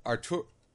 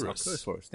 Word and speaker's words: Arcturus: Arcturus. – Yes, Arcturus.